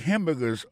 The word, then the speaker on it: hamburger: N'a pas remarqué que dans le mot hamburger, ça comme tu as dit, il y a trois syllabes là-dedans. Quand tu appuies et puis plus ça, c'est sous premier syllabe là.